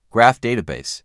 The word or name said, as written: graph database